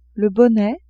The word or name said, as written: bonnet